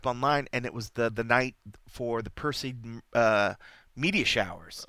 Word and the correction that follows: Perseid: I know I'm butchering that name or whatever, but the Perseid, I just always say Perseid Meteor showers.